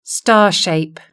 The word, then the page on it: star shape: British English